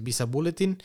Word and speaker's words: Visa Bulletin: Así se, esta, la pronunciación sería así. Es Visa Bulletin